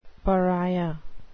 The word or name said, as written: pariah